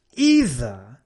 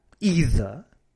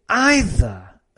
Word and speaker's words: either: So either could be pronounced like that, either. O también se puede decir either as well. Either, genial. Either, también me gusta. Lo que no mola nada es either. Either, fatal. It's not either, it's either or either.